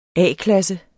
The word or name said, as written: A-klasse